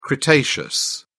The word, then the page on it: cretaceous: British English